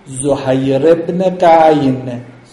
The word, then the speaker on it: زهیر ابن قعین: زهیر ابن قعین، ظاهرا تلفظ درستش اینه، حالا ما میگیم زهیر، زهیر ابن قعین، ظاهرا همین هزار تا رو ایشون میگه، میگه اگر هزار مرتبه من کشته بشم بازم دست از امام حسین برنمی‌دارم.